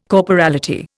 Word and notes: corporality: Indian

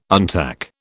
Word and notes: untack: British English

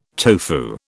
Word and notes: tofu: British English

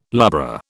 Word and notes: lubra: British English

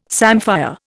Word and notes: samphire: Indian

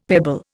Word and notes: pebble: Indian

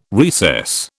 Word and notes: recess: British English

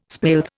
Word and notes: spilth: Indian

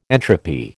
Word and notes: entropy: American English

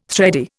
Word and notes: thready: Indian

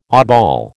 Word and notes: oddball: American English

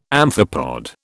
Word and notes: amphipod: British English

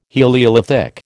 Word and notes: heliolithic: American English

